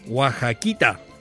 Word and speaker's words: Oaxaquita: Oaxa. No, se pronuncia Oaxaquita, eso, Oaxaquita de Oaxaca.